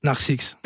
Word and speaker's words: Narcix: Parlons de ton nouvel EP que t'as appelé Narcix. C'est comme ça qu'on prononce? – Yes, Narcix.